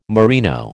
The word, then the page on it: merino: American English